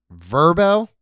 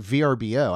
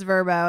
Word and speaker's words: VRBO: Um, Airbnb, um, Verbo. Did you know it's pronounced verb? I just called it VRBO.